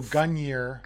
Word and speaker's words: Gungnir: I would say, yeah. I don't think you pronounce the second N. I mean, my old Norse isn't very good, but. – yes, so Gungnir.